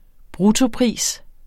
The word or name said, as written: bruttopris